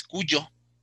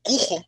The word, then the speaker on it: Cujo: que es Cuyo. Cujo, le decía yo, porque lo leí, lo pronunciaba como lo veía escrito con J.